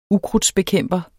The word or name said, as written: ukrudtsbekæmper